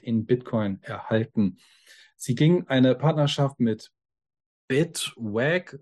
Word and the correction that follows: Bitwage: oder keine Ahnung, Bit wie der Swag, Bitwage.